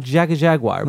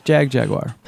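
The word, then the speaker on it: Jagjaguwar: I have always said Jaga Jaguar, but there's no A in there.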